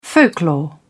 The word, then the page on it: folklore: British English